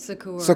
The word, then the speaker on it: Secours: Secours.